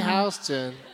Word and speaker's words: Houston: Yeah, it's spelled Houston, but you say Houston.